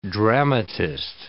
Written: dramatist

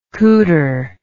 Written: cooter

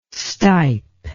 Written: stipe

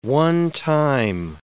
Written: onetime

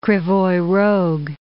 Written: Krivoy Rog

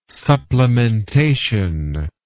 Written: supplementation

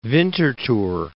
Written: Winterthur

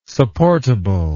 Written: supportable

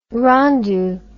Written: rendu

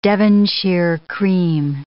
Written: Devonshire cream

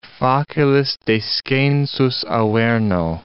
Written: facilis descensus Averno

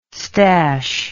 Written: stash

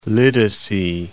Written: Lidice

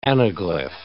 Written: anaglyph

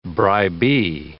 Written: bribee

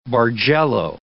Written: bargello

